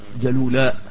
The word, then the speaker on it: جلولاء: خلاص، فشوف بيقول ثم سار حتى انتهى الى جلولة، تنطق الكلمة هكذا جلولاء.